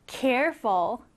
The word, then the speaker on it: careful: 두 번째 단어는 careful. careful. 자, 여러분, careful은 이렇게 앞에 강세가 들어갑니다. 앞에 들어가서 careful 이렇게 발음을 해요.